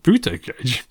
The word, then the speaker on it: Buttigieg: That's pronounced Buttigieg. – It's pronounced Buttigieg.